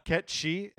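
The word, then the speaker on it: Cait Sith: I mean, just for the record, it is spelled K-I-T. – C-A-I-T. – C-A-I-T space S-I-T-H. Kate Sith, but it's like Scottish or something. Ket she anyway.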